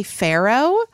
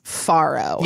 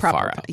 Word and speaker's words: farro: Faro, F-A-R-R-O. – Uh-huh. – I hear a lot of people say Pharaoh, but... – Sure. I mean, that seems like how you would pronounce it in an Americanized way. – The New York Times indicates Faro. – Yeah, I definitely say it Faro.